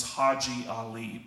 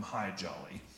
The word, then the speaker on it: Haji Ali: And one of the camel drivers, his name was Haji Ali, but nobody said his name right. They all called him High Jolly.